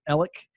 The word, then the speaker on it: Alex: My first name is spelled A-L-E-X. The world, literally, the world pronounces that Alex. My family pronounces it Alec.